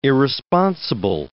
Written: irresponsible

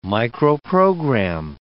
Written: microprogram

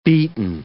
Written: beaten

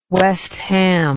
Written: west ham

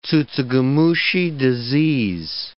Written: tsutsugamushi disease

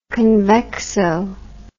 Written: convexo

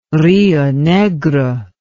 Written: Rio Negro

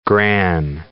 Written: gran

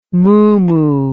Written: mumu

